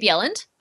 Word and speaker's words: Bjelland: Uh, I'm also at Tammy Bjelland.